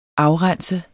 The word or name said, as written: afrense